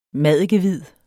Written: maddikehvid

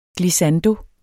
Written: glissando

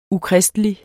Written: ukristelig

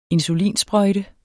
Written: insulinsprøjte

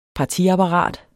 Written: partiapparat

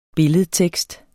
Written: billedtekst

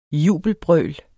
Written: jubelbrøl

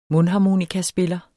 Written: mundharmonikaspiller

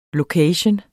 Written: location

